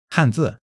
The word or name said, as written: han4 zi4